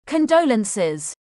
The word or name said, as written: Condolences